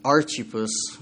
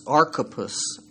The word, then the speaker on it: Archippus: It kind of sounds like it should be Archippus, but it's Archippus is how his name is pronounced.